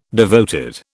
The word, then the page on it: devoted: British English